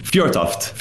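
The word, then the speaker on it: Fjørtoft: Fjortoft. Fjortoft. So you were pretty close.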